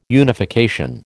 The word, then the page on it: unification: American English